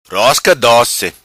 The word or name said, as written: Rosca Doci